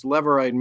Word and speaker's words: Levirate: It looks right. Like it has to do with the tribe of Levi, but it has nothing to do with Levi. It's spelled like Levi, but it's Levirate marriage, which was a custom at this time and then became a law in Deuteronomy.